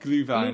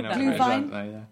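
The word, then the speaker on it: Glühwein: Gluheim, is that how you pronounce it? – Gluevine. – Gluevine.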